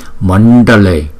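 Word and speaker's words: Maṇḍale: Mandale, Mandale. Chữ N và chữ D có dấu chấm ở dưới, chúng ta phải cong lưỡi lại, lấy hơi từ trên vào miệng chúng ta đưa ra.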